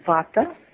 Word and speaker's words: vata: And the 3 themes, as many of our audience knows, are called vata, pitta, and kapha, which some people pronounce vata, pitta, kapha, but it's vata, pitta.